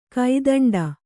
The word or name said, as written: ಕೈದಂಡ